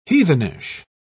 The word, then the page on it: heathenish: British English